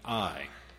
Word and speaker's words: Ai: and we see their defeat at Ai. And I realize there's a little bit of debate. Do you pronounce it I or AI? I'll be pronouncing it I, following the Hebrew.